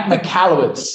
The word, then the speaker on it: Michalowicz: Kris has designed and implemented this simple system for people like world renowned business author Mike Michaelowitz. I probably said that wrong. Mike Michalowicz. – That's it, Mike Michalowicz.